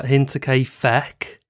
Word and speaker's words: Hinterkaifeck: How would you pronounce it? – Hinterkaifeck. Not Hinterkaifeck.